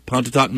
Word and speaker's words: Pontotoc: Pontotoc, Mississippi. Did I say it right? – Yeah. – Pontotoc, Mississippi.